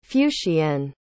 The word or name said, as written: fuscienne